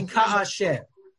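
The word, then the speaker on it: ka'asher (כאשר): But if you say when in the declarative, meaning when this happens, you say ka'asher. And in the pasuk here, it doesn't say ka'asher nasi, when the nasi sins, but asher nasi, when the nasi sins, which is incredibly unusual conjugation.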